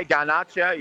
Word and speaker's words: Garnacho: What about the goal by Alejandro Garmocho? – Alejandro. – The bicycle goal. Is that up? Am I saying his name correctly? Garnacho. – Yeah, Garnacho.